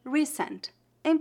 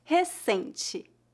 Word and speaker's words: recente: Note que adicionamos a letra E ao final, recente. Novamente, a letra R no início é pronunciada como a letra H do inglês e a sílaba tônica é a penúltima. E as letras T e no final são pronunciadas como ti, recente.